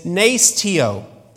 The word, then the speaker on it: nesteuo: So, so the Greek here for word for fasting is nesteuo.